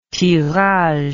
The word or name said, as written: tirage